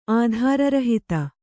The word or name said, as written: ಆಧಾರರಹಿತ